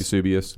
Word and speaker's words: Eusebius: Eusebius.